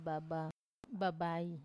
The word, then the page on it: baba babai: Wamesa